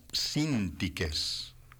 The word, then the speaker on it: Síntique: Entre las rarezas tenemos algunas joyitas para guardar en el recuerdo, como felicitar a todas las síntiques, Santa Síntiques, con acento en la primera ahí.